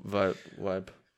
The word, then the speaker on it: Vibe: Apropos Weiben. Apropos Sachen falsch aussprechen. Ach, ich dachte so, apropos Weiben. – Apropos Vibe.